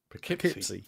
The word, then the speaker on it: Poughkeepsie: The third one I watched, because our friends over at the Final Guys, had reviewed it, and it was the Poughkeepsie tapes. I think that's how you say it. – Poughkeepsie, yes.